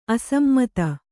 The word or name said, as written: ಅಸಮ್ಮತಿ